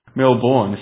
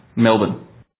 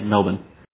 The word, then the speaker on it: Melbourne: It's Melbourne. It's like if you just imagine it's M-E-L-B-N and there's no vowels in the second syllable, that's how it's pronounced. So, Melbourne.